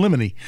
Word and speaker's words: limine: In addition to that pretrial conference, they are going to discuss the state's motion to, and I don't know if I'm pronouncing the word right, lamine. Does that sound right? – Motion in limine. – Limine, yeah.